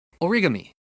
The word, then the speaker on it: origami: In Japan, here in Japan, they don't put any stress on it, so it's origami. It's just like flat.